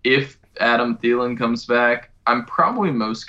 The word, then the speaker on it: Thielen: And DJ, Adam Thielen? – Yeah, Adam Thielen, that guy.